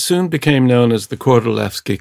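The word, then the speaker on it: Kordylewski: It looks like Lewski, but it's Levski because the Poles pronounce a W like a V. Kordolewski.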